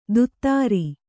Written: ತುತ್ತಾರಿ

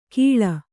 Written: ಘೀಳ್

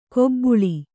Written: ಕೊಬ್ಬುಳಿ

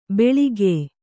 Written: ಬೆಳಿಗ್ಯೆ

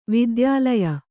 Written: ವೈದ್ಯಾಲಯ

